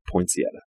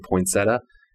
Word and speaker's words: poinsettia: She called in, got it right, and it's forever sealed in my head that that is how you say that word, poinsettia. There were all these callers calling in saying poinsettia, and the, you know, sarcastic radio DJs were not letting them win because that wasn't the correct way to say that word.